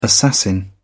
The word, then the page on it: assassin: British English